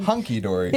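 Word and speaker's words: hunky-dory: hokey dory, mm-hmm. but in your, did I say that right? – You said hunky dory. – Yeah, hunky dory. – Hokey pokey, hunky dory, not hokey dunky.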